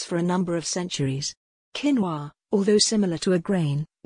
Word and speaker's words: quinoa: Quinoa Quinoa Quinoa, pronounced quinoa is a superfood that has only gained popularity over the last few years.